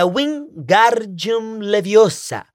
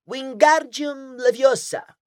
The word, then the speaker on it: Wingardium Leviosa: É Wingardium Leviosa. O gar é bem pronunciado e longo.